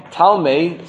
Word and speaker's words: Ptolemy: Ptolemy. Ptolemy, spelled with a P.